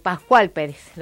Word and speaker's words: Pascual Pérez: 12 años después del título logrado por Pascal Pérez, Pascual Pérez, lo dije mal, se impone ante el japonés Kazuyoshi Takayama, en Tokio.